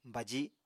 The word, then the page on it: baji: Apatani